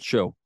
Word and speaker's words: shew: I just learned recently that if you pronounce, the correct pronunciation for S-H-E-W is still show.